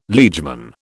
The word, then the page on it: liegeman: British English